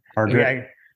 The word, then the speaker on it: Hargrett: All right, and actually, make sure that I'm pronouncing this correctly. Hargrit Library? Am I saying that right? – Yeah. – Okay. – Hargrit. – Okay, it is Hargrit.